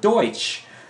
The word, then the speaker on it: Deutsch: So, you know, people say Pennsylvania Dutch, right? But they're not Dutch. It's a mispronunciation of the word Deutsch.